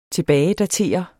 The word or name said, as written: tilbagedatere